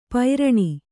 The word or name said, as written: ಪೈರಣಿ